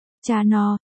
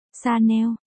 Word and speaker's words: Chanel: Tương tự với hãng Chanel nổi tiếng với âm N, nếu như đọc là Chanel thì nó mang nghĩa là kênh, cách đọc đúng là Chanel, cơ, còn có Givenchy, Givenchy, đọc loạn ba chữ này lên là được, sau cùng là nhãn hiệu nước hoa.